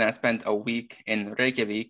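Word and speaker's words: Reykjavík: Reykjavik. – Oh, you've even got the accent and everything. – You have to roll your R's and yeah, you have to have it there.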